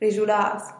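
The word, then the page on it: rizhulaaz: Teotitlán del Valle Zapotec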